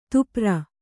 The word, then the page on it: ದಪ್ಪರಿ: kannada